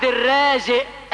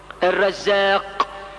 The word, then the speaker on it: الرزاق: يقول لك الحج عبد الرازق، اسمه الرازق ولا الرازق؟ الرزاق. الرازق، انت عارف الرزق هو ايه؟ المصيبة. يبقى ده اسمه عبد المصيبة. اسمه الرزاق مش الرازق.